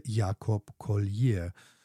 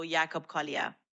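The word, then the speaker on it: Jacob Collier: Und zwar heißt er Jakob Collier. Ich hoffe, ich habe ihn richtig ausgesprochen. Jakob Collier ist, was ist der genau, Jetty? – Oh, Jakob Collier. – Ja, du hast seinen Namen genau richtig ausgesprochen.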